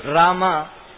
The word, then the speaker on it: Rama: So, even though Rama, it sounds like only two syllables, but there are three syllables. Ra, A and Ma.